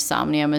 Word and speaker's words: Somnium: S-O-M-N-I-U-M. So it's Somnium. Like insomnia, but Somnium.